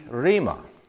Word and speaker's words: rhema: The word is spelled R-H-E-M-A, rhema, means word.